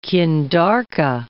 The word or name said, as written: qindarka